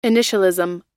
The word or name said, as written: initialism